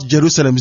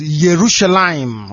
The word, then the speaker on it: Yerushalayim: Because the same way, people don't know that there is no, in fact, it's not Jerusalem, it's Yerushalayim. Y, not J.